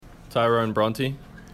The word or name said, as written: Bronte